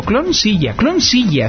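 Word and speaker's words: Clonezilla: Pues también, cloncilla. Es que la pronuncio bien en inglés, clon, o sea, es clon seguro, clon silla es el nombre pronunciado en inglés.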